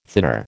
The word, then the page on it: thinner: American English